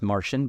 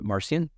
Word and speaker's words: Marcion: It could be pronounced Martian, but for obvious reasons, we just kind of pronounce it Marcion, you know, because Martian is something else, which was a specific heretic, but like a heretic who was claiming that he was a Christian and a church teacher.